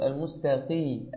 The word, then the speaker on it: المستقيم: المستقيم يا أخي الحبيب، القاف كما ترى مكسورة، والقاف المكسورة والقاف والغين والخاء، الثلاثة أحرف إذا كانوا مكسورين أو سواكن وقبلهم وسبقوا بكسر، أو سواكن وسبقوا بياء. ففي هذه الأحوال الثلاثة يكون الأغلب في نطق القاف والغين والخاء الترقيق وليس التسخين، فقل المستقيم المستقيم المستقيم هكذا، وجزاك الله خيرا.